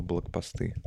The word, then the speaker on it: блокпосты: Какое ударение у слова блокпосты? Блокпосты, ударение. Блокпосты. Правильно.